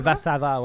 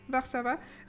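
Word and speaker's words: Warszawa: sinon, Varsa je sais pas comment le prononcer, mais c'est Varsovie, là. – C'est Varsava, ouais. – Varsava, euh, qui est la première chanson du deuxième côté,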